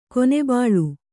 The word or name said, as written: ಕೊನೆಬಾಳು